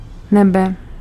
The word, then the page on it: nebe: /nɛbɛ/